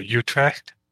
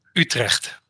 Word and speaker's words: Utrecht: Utrecht. No, it has a hard G, which is pretty much impossible for Americans to say.